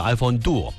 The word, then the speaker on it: iPhone Duo: 它这个特别容易被咱念成拼音叫朵啊，但是它应该叫 iPhone Duo。